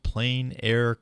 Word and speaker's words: plein air: The Jefferson Land Trust is inviting families to join the Family Plein Air class. And I'm not pronouncing that with the correct French accent, but plein air. It's open for in the opened air.